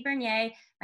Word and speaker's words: Vernier: You can follow me on all social media platforms, including YouTube, at Missy Bernier. My last name is spelled V-E-R-N-I-E-R.